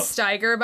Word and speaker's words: Steiger: It is Steiger, by the way, because if we're doing with pronunciation where the I comes second. – Oh, that's true. – It's the I. If the E comes second, it's the E.